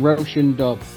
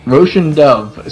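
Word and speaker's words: Róisín Dubh: It's pronounced, I think it's pronounced Roshan Dove. – Roshan Dove. – So Roshan Dove, in parentheses, Black Rose, colon, a rock legend.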